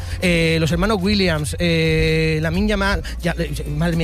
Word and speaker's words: Lamine: ¿Lo has dicho bien, Lamine? – Lo he dicho bien. Es que me cuesta. Lamine.